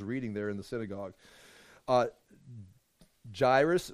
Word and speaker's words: Jairus: Jair, as in chair with us, Jairus.